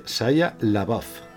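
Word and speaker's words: Shia LaBeouf: Eh, Saya, Saya Lebu o algo así. – Venga, David. – No tengo ni idea. – Bueno, se, es, se pronuncia Saya Labaf.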